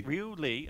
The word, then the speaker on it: Ryu Lee: how do you pronounce that? Ryo Lee? – Ryu, I think it's Ryu. – Ryu Lee, okay.